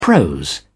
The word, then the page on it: prose: British English